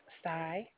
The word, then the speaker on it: Sai: My name is Sai. Can I have your zip code to pull up your account? – Say your name again? – Sai. – Spell that, please. – S-A-I.